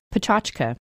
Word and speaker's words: pechakucha: This 20 by 20 method is known as pechakucha, or, closer to the proper pronunciation, pechachka.